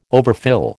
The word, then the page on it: overfill: American English